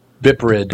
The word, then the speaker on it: BPRD: It's pronounced Bipprid.